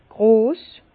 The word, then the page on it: gross: Bas-Rhin; Herrlisheim